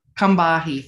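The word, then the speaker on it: Combahee: And today, it's actually a quote from an article by our guest, help me if I'm mispronouncing. Is it Kambahi? – Kambahi, thank you.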